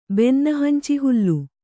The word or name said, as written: ಬೆನ್ನಹಂಚಿಹುಲ್ಲು